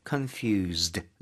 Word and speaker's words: confused: 중간에 S가 있는데요. Z 발음으로 해주시면 좋아요. – Okay. confused. Z-E-D처럼요.